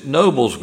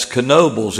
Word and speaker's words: Knoebels: he pronounced it Knoebels Grove. And somebody said, that's Knoebels.